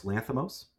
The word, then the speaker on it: Lanthimos: Well, the Poor Things, I can't pronounce his name, the Greek man, Yorgos Lanthimos.